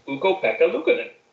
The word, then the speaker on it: Ukko-Pekka Luukkonen: So, rounding us out in our top five, a goalie with an amazing name that I'm probably going to butcher. It's Ukko-Pekka Luukkonen. – I think you got it. – Ukko-Pekka Luukkonen.